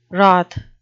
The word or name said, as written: wroth